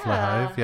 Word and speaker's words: Flahive: How do you say her name? – Yeah. – Flahive. Yeah.